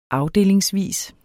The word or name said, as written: afdelingsvis